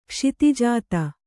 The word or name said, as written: ಕ್ಷಿತಿಜಾತ